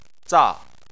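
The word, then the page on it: za: tone 4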